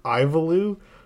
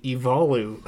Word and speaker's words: Ivalu: And again, with pronunciation correction, our guess, we called it Ivalu, is actually Ivalu.